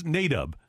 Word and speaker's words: Nadub: It's like one word. Just Nadub. Nadub. – Nadub. Okay, so it's basically one word with two syllables. Nadub.